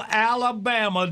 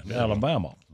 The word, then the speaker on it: Alabama: And they'll pronounce the L down there. Alabama.